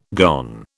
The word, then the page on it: gone: British English